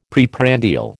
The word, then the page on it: preprandial: American English